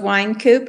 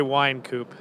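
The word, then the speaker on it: Wynkoop: I'm going to say Wine Coop. – You're going with wine, not win. – Yeah, wine. Yeah. – Why? – Because there's a Y, I guess. – W-Y-N-K.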